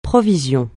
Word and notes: provision: francais

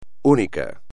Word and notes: único: espagnol

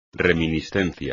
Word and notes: reminiscencia: espagnol

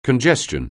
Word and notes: congestion: anglais

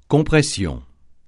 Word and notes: compression: francais

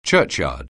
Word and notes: churchyard: anglais

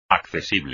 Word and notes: accesible: espagnol

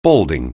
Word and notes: balding: anglais

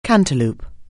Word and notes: cantaloup: anglais

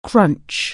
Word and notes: crunch: anglais

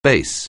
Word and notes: base: anglais

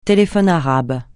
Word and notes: téléphone arabe: francais

